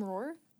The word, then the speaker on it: Rohr: I follow Father Richard Rohr. I get his devotional every day. He just has some great, great thoughts. – Is his last name Rohr? – Rohr. – Like lion? – Actually, it's spelled Rohr.